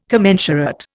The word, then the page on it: commensurate: Indian